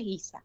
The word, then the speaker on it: Giza: Giza, como Giza. Si fuera, en vez de, como si le pusiéramos una U y una I, como de guiso, pero Giza. – Claro, exacto.